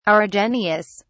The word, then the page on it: argenteous: British English